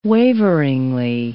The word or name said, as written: waveringly